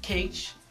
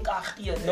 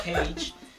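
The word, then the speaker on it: Kage: Cage spelled with a K. Cage, because most people like. People who just see that name on film, like, hey, Kahia, no, it's Cage.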